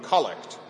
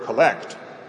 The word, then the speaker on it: collect: collect. Pronounced that way rather than the verb collect.